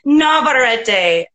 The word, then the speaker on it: Navarrette: Navarrette.